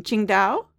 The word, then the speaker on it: Qingdao: You have an interesting history, one that begins in, I'm probably going to blow this pronunciation, Qingdao, China. – That is correct. You pronounce it perfectly fine.